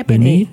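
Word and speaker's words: Benee: Benny heißt die. Ja, ist das richtig ausgesprochen? – Ich denke, ja, Benny. – Benny, genau.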